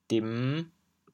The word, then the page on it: 沈: dim5; 潮阳